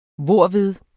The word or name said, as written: hvorved